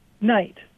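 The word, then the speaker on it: knight: Night. – Right. So in Middle English, it was likely pronounced knicked.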